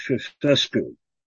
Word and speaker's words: Ceaușescu: I think his name was Nicolae Căsăcu from the Romania. I probably pronounced that wrong. You can do a better job on that. – I think it's pronounced Ceaușescu.